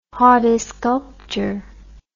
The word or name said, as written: hortisculpture